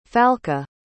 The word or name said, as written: falke